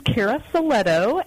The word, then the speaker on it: Cara Silletto: So my name is Cara Silletto, and our company, Crescendo Strategies, we started by focusing on the millennial generation and helping bridge the generational gaps.